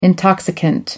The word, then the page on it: intoxicant: American English